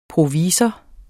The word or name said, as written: provisor